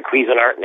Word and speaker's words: Cuisinart: Cuisinart Resort Golf. Cuisinart, is that how they pronounce it? – Cuisinart. It's the same as the people who make the cooking devices.